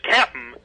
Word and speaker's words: Cap'n: she accidentally mispronounced it as Cap'n, CAP'N, and they liked the way it sounded so much they decided to change the whole name just on the basis of that.